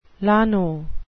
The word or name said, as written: llano